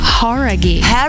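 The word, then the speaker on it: Jauregui: Horaghi. Como é? – Horaghi. – Horaghi. – Então a gente tava falando errado, cara.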